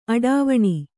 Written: ಅಟ್ಟವಣೆ

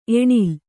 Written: ಏಣೇಲು

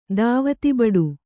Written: ಧಾವತಿಬಡು

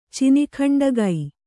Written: ಚಿನಿಖಂಡಗೈ

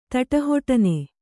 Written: ತಟಹೊಟನೆ

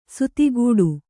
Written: ಸಾತಗಡಿ

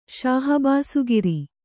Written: ಶಹಬಾಸುಗಿರಿ

